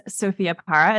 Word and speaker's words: Sophia Parra: My name is Sophia Parra, as you so beautifully pronounced.